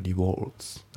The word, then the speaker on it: rewards: で、 Have got が V の、 U が S の、 A rewards card が O で、ポイントカードはお持ちですか、ね、このリボードっていうね発音ね、リワードじゃないですね。このなんか綴りだけ見るとね、リワーズっていう感じに見えるんですけど、まあ本当の発音はリボールズだね。